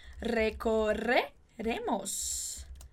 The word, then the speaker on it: recorreremos: Entonces, nosotros y nosotras recorreremos. Opa. Recorreremos. Recorreremos. Remos. ¿Ok? Mucho cuidado con esa pronuncia.